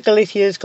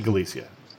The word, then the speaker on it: Galicia: You're pronouncing it with a Castilian pronunciation, so you're saying Galicia and I'm saying Galicia, and I may not be able to change my pronunciation. – The answer to either. And in fact, in Galician, it's Galicia with a Z.